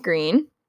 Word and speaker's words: Gruene: So since the very beginning of the town of Green, Texas, which is spelled G-R-U-E-N-E, but it's pronounced Green.